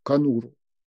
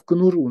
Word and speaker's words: конуру: Значит, опять темно и понуро, сердце возьму, слезами окапав, нести, как собака, которая в конуру несет перееханную поездом лапу. Вообще-то в конуру, но мы же понимаем, что в поэзии ударения перемещаются в угоду ритму, размеру.